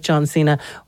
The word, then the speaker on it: John Cena: So if Little Brother isn't your thing and you do like John Cena, well then we might...